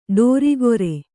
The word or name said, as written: ಡೋರಿಗೊರೆ